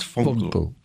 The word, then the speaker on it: Vonkel: V you pronounce like an F, so it's Vonkel.